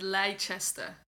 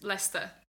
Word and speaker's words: Leicester: And I called it Leicester. – Leicester. – I had like Leicester. I said it like a very Norwegian, I would guess. But it's Leicester. But it's spelled L-E-I. So it's just Leicester.